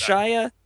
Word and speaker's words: Shia: Is it pronounced Shia? – Yes.